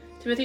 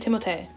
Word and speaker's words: Timothée: Henry Golding, Timothy Chalamet. – Timothée.